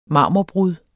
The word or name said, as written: marmorbrud